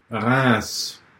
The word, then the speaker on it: Reims: Reims.